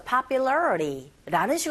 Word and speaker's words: popularity: 이런 경우 T소리가 R소리로 들려지게 되죠. 그래서 popularity라는 식으로 발음하시고 after 긁어주시면서 after being이 아니라 being라고 발음하셔야 돼요.